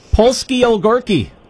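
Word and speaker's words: Polski Ogórki: So it's the Vix, and I don't know how to pronounce them, the Borshki, Polski pickle. – Polski Ogorki. – Yes. Thank you.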